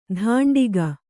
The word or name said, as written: ಧಾಂಡಿಗ